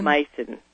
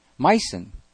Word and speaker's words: Meissen: Meissen. – Meissen.